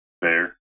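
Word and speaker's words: Bexar: Oh, well, just like, so in Texas, there's B-E-X-A-R. – Bear. – It's Bear County.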